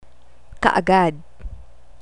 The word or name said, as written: kaagad